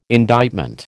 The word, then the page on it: indictment: American English